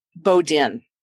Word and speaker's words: Bodin: Bodin. – Bodin. – It's spelt the French way, but not pronounced the French way because I don't know.